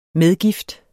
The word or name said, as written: MEDGIFTER